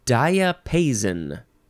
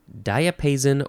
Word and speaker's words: diapason: Diapason. Diapason or diapason. That's how you say it. D-I-A. P-A-S-O-N, noun from circa 1501.